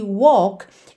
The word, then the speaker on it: walk: Lembrando sempre que walk é uma palavra que engana um pouquinho, porque esse L não vai ser pronunciado como L mesmo. A gente não leva a língua lá no céu da boca. Walk. E sim, walk.